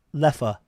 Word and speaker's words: Leffe: Leffe. – Leffe. See, then how is the E and the E pronounced E and uh? And there's, I don't get an umlaut, nothing.